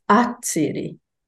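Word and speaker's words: atsiri: But in this dictionary, dating to 1647, it begins with an a, it's a tsiri. So the stress is on the second syllable, which is in fact where you would expect it.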